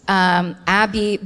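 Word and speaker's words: Aby: and it's by a person named Abby Bentham. It's A-B-Y, so I'm pronouncing it Abby. It might be A-B, but the article is called Manchester's Forgotten Monster.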